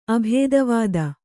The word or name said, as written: ಅಭೇದವಾದಿ